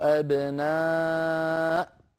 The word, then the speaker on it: أبناء: انظروا إلى كلمة أبناء كيف كتبت بين يدي رسول الله صلى الله عليه وسلم، كتبت همز ألف باء نون واو ألف، لا الواو هي كرسي الهمزة، والألف التي بعدها زائدة في الكتابة، الله أعلم ما الحكمة منها، هكذا كتبت بين يدي رسول الله، وهذه الألف لا تنطق. أما الواو التي هي كرسي للهمزة فهي أيضا لا تنطق، والذي ينطق إنما هو الهمزة،